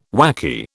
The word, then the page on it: wacky: British English